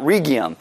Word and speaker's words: Rhegium: And from there we made a circuit and arrived at Rhegium." And I actually looked up how to say that, so apparently that's how you say it.